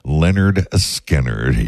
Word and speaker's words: Lynyrd Skynyrd: Pronounced Lynyrd Skynyrd.